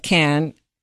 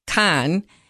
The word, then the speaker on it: Cannes: But the reality of Cannes, or let's say it right, Cannes, is that it's a place where you go and do business.